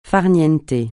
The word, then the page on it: farine: francais